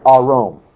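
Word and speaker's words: arom (ערום): The word arom. It's spelled Ayin, Resh, Vav, Min. Arom.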